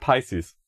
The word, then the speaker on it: Pisces: Offizielle Aussprache ist Pisces.